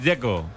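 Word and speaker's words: Džeko: Bruno dice que es Dzeko. – Como tirando a J, Dzeko o algo así.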